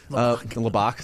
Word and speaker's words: Lubbock: You know, if North Carolina fires, if Texas Tech moves on, like he's not going to go live in Lubbock. Like that ain't happening. – Lubbock.